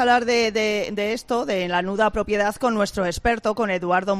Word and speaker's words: nuda propiedad: Sí, nuda propiedad, como nudo, pero en femenino, nuda propiedad.